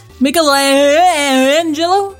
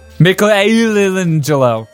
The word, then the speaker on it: Michelangelo: Michelangelo? – Michelangelo.